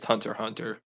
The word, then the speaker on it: Hunter x Hunter: Uh, hu- the Hunter x Hunter, I'm almost 100 percent certain that's pronounced just Hunter Hunter.